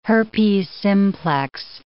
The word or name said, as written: herpes simplex